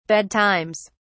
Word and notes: bedtimes: British English

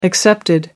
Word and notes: excepted: American English

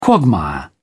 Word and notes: quagmire: British English

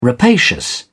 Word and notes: rapacious: British English

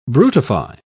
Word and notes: brutify: British English

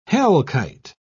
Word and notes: hellkite: British English

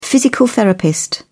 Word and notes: physical therapist: British English

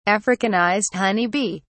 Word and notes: africanized honey bee: British English

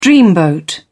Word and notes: dreamboat: British English

